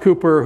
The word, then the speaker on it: Cowper: William Cowper, or I think sometimes his name is pronounced Cooper,